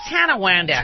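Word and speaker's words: Tonawanda: He said the Tawanda News. It's Tanawanda.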